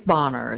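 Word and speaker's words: Boehner: My last name is Bonner. It's spelled B-O-E, H like in Harry, N like in Nancy, E-R.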